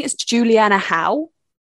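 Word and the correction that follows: Hough: Juliana Huff? – Juliana Huff, Huff? – yes. – Is it Huff? Okay, good.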